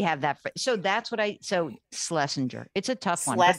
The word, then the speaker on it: Schlesinger: So we just say, instead of Schlesinger, we say Schlesinger. I said, one way to remember it is like S-L, like S-L, pretend there's no C-H there. And it's a soft G, like a J, not a GER.